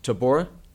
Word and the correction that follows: totoaba: Totoaba. – Totoaba.